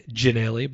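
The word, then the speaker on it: Ginnelly: Now, I did tweet Josh early just to confirm that it is Ginelli, because I watched a few clips and most were pronouncing it Ginelli, but I did see a couple of clips where the commentators were going with Ginelli, but that does seem unlikely.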